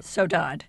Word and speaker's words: saudade: Saudade.